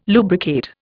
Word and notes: lubricate: Indian

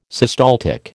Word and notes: systaltic: American English

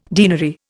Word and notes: denary: Indian

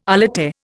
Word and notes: alate: Indian

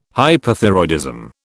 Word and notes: hyperthyroidism: British English

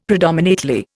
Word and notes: predominately: Indian

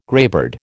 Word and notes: greybeard: American English

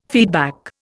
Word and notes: feedback: Indian

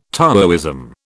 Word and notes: Taoism: British English

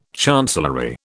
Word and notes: chancellery: British English

